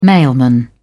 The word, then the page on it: mailman: British English